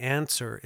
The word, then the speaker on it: Anser: Now, for all you tech heads out there, the Answer, it's spelled A-N-S-E-R.